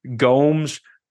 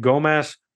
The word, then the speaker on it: Gomes: Uh, Denise Gomes their their announcer pronounced it Gomez, but Jon Anik has said Gomes, so I'm going Gomes.